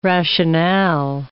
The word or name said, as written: rationale